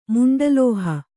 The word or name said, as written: ಮುಂಡಲೋಹ